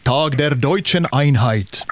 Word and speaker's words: Tag der Deutschen Einheit: Ja, om vi säger det riktigt officiellt på tyska så är det Tag der Deutschen Einheit. – Nej, det är så. Så är det. Fint uttal där.